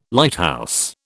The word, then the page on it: Lighthouse: British English